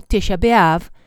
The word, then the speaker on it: Tisha B'Av: El día 9 del mes bíblico de Av, pronunciado en hebreo Tisha B'Av,